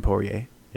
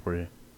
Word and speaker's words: Poirier: I always pronounce his last name wrong, but I think it's Dustin Poirier. – Yeah, Poirier.